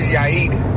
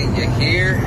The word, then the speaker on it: Yair: My name is Yair, but white people always say Yair. Like they always say something different. It's not that hard, but it's not that hard.